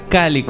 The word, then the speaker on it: Cale: Se escribe así, JJ Cale, como suena, Cale.